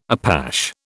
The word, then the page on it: Apache: British English